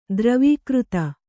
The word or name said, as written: ದ್ರವೀಕೃತ